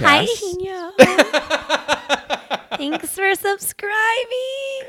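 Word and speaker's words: Higinio: Okay, his name is Ihinyo, and it's spelled H-I-G-I-N with the tilde above it, I-O.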